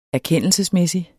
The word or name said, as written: erkendelsesmæssig